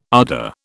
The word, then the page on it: ardour: British English